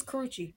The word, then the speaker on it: Karrueche: So, bad chick of the week is Karrueche. Is that how you pronounce her name? It's Karrueche.